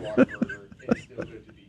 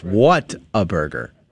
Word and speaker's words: Whataburger: Despite the fact that Joe doesn't know how to say water burger, it's still good to be here. In spite of it all. All right. I'm not anti Whataburger. I just prefer to say it correctly. It's Whataburger. Oh, wow.